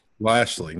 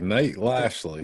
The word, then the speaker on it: Lashley: Lashley. Nate Lashley.